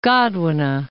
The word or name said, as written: Godwine